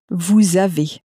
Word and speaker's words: vous avez: And so the word for you in French is vous, which is spelt V-O-U-S, and the S is not normally pronounced.